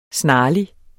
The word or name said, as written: snarlig